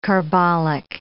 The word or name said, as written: carbolic